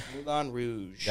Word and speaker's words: Moulin Rouge: Moulin Rouge.